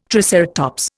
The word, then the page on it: triceratops: Indian